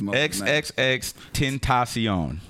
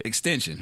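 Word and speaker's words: XXXTentacion: XXX Tentacion. That's how you say it. – Extension.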